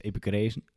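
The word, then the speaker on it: Epicureeërs: De Epicuriërs, zeg ik dat goed? Zoiets, Epicureus.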